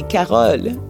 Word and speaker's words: Carole: So in French, it would be Carole.